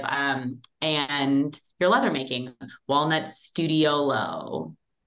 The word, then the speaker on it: Studiolo: Did I say that wrong? – No, you didn't, but it's okay. Studiolo is an Italian architecture term for a little studio.